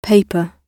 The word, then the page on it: paper: British English